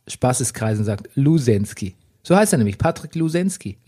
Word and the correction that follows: Losensky: Losensky, Entschuldigung. Ach so. Aber man möchte natürlich automatisch, wenn man Losensky liest, das als Lusensky aussprechen.